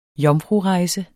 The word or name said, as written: jomfrurejse